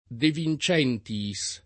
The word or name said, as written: De Vincentiis